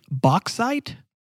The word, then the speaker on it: bauxite: Bauxite.